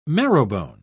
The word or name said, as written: marrowbone